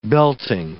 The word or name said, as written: belting